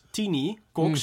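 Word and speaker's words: Tiny Kox: His name was Tini Cox. – Mm, yes. – But you spell it as T-I-N-Y.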